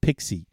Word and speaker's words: PXE: That's PXE and pronounced Pixie.